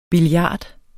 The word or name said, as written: billiard